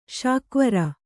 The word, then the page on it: ಶಾಕ್ವರ: kannada